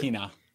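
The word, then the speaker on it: Westbrook-Ikhine: Nick Westbrook-Akyine, I hope I'm saying it correctly. – I think it's Akina. – It's Akina? Okay.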